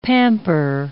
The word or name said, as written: pamper